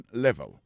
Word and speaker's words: level: What's your education level? Level là, c'est niveau. Là encore, si nous voulons parler de accent tonique, nous allons dire what's the level of your education? Et accent tombé sous premier syllabe là.